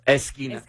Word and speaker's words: esquina: Esquina. – Esquina. – That's right. Good. Great pronunciation.